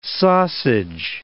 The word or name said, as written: sausage